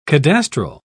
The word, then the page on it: cadastral: British English